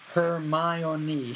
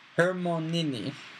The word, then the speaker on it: Hermione: Hermione was now teaching Crumb to say her name properly. He kept calling her Hermione. – I like that, though. – Yeah. Hermione, she said slowly and clearly. Hermonini. Close enough, she said, catching Harry's eye and grinning.